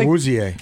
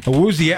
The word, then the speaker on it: Awuzie: Did I say that correctly? – Awuzie. – Awuzie. Oh, the IE is two syllables. Awuzie, drafted by the Cowboys, then played some time in Cincinnati, including playing in a Super Bowl.